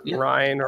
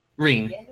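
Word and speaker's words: Ryne: Is that the official pronunciation for it? Is Reen, not Ryan or whatever? Reen? – Reen. Yes, okay.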